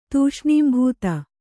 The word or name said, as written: ತೂಷ್ಣೀಂಭೂತ